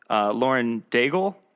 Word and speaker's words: Lauren Daigle: And that seems very, it's funny you should say that, because it reminds me of, I think the singer's name is, Lauren Daigle, if I'm saying that correctly. – Yeah. – I seem to remember. – That's her name. That's how you pronounce it.